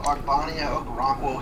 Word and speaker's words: Ogbonnia Okoronkwo: All right, I'm going to go Ogbonnia Okoronkwo here.